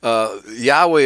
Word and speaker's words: Yahweh: Yahweh is the one that is preferred by, I'd say, 90% of scholars, okay, who have dealt with this.